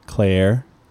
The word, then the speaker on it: Cleyer: But this guy, Andreas Klayer, or Klura, How would you pronounce his last name? Can you see it? It's spelled C-L-E-Y-E-R. – Claire. – Nice. – Yeah, yeah, yeah. – You all said, right, you all got right.